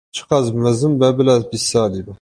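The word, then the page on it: mezin: Kurdish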